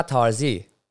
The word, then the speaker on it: Tarzi: Pasha Tarzi, eller förmodligen uttalar man Tarzi. Är det Pasha Tarzi? Det är Tarzi.